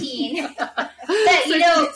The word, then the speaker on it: Satine: Satine, which is how Satan is pronounced in South Park.